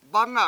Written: bAnga7

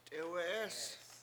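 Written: t'éwe'é:s

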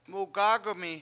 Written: mugAg1mi

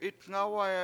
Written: itNGAwaya

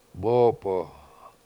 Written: bOOp7o7

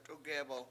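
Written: t'ugébɨl

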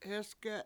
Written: hEsge7

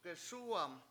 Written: gesUUwam

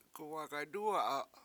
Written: guwAgaydUwa7a

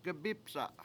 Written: gabIpsa7

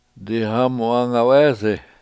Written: dihAmu7AngawEEsi

